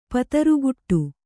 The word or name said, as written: ಪತರುಗುಟ್ಟು